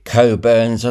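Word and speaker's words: Cockburn's: Although it's spelt Cockburns, they pronounce it Coburns.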